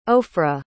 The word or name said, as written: ofra